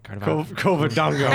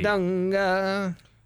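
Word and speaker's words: Covadonga: So that's Kardavoga. Right? – Kovadonga. – Oh my goodness. It's hard to pronounce. – Yeah. – Sorry. – Kovadonga.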